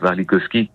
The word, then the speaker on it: Warlikowski: C'est Europa, c'est d'après le serment d'Europe de Wajid Mouawad et c'est mis en scène par Christophe Warlikowski. J'espère que j'ai peut-être pas bien prononcé son nom. – Non, ça fait bien Christophe Warlikowski.